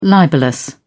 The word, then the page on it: libellous: British English